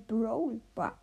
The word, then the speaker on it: Brawl Ball: Äh, äh, oder Broball äh, Brawl Ball, wenn ich das jetzt richtig ausspreche.